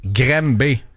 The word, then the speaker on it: Granby: Est-ce que tu peux, s'il te plaît, nous appeler Graham B? – G-R-A-N-B-Y.